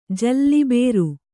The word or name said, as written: ಜಲ್ಲಿಬೇರು